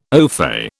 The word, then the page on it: ofay: British English